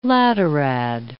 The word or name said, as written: laterad